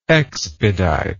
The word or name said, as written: expeditely